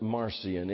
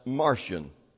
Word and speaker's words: Marcion: Just to see it, you might pronounce it Marcion, M-A-R-C-I-O-N. But I cheated. I've got a program that tells me how to pronounce Bible names. And it tells me that it's pronounced Martian, as like one of the little green men.